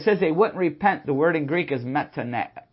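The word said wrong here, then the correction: Metanoeo (μετανοέω): It means to change your mind. Metanoeo. It's been a while since I did my Greek pronunciations.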